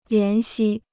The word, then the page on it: 怜惜: lián xī